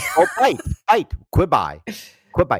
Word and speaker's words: Quibi: Quibi, yes. – Quibi, not Quibi, but Quibi. Like, don't yes. – quibble, it's pronounced Quibi. Okay. – Yeah, like quick bites. – That's right, quick bites, Quibi.